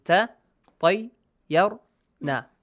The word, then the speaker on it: تطيرنا: pronounce each part yes each vowel sound okay separately then join them together, right? Like ta tay yar na.